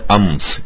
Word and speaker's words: امس: اور ایک اس کا تلفظ ہے امس، تو اس کے معنی ہے گزشتہ کل۔